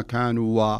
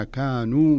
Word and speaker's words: كانوا: لاحظ الألف اللي تبعد الواو ما تقول ما كانوا و لا تنطقها لا وصلا ولا وقفا ما كانوا به ولا كأنك انظر إليها.